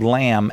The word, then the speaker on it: lamb: You just put in the promo code LAMB, L-A-M-B. But make sure you use a silent B.